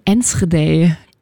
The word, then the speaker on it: Enschede: In Enschede. – Im Osten Holland. – Du hast das direkt so niederländisch ausgesprochen.